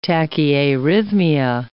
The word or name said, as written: tachyarrhythmia